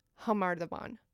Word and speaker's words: Khamar Daban: But as we just heard from the song, it's pronounced Khamar Daban. – Right. – Like an H noise.